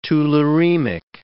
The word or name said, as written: tularemic